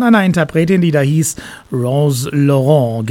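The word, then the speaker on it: Rose Laurens: Genau, so wird sie ausgesprochen. Viele meinten ja, weil es später auch eine englische Version von diesem Song gab, das ist Rose Lawrence oder wie auch immer. Nee, es ist Rose Laurent.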